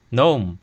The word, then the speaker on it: gnome: 那么言归正传，我们对比拉丁语、德语中 gnome 这个词的词首字母 g 都发音来看，现代英语中读作 gnome，忽略了词首 g 的发音，也是语言历史演变过程中发生的一种辅音弱化现象，称之为辅音脱落。